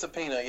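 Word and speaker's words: Opeña: Okay, The Avengers is written by Jonathan Hickman and penciled by Jerome Opina. I hope I pronounced that right. – Do you reckon that's right? – I think it's Opina, yeah.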